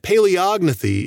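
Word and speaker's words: Palaeognathae: That's how I want to say it, Paleognathae. But I think that Paleognathae is the correct pronunciation.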